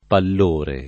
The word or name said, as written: pallore